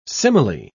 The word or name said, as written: simile